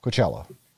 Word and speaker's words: Coachella: Coachella.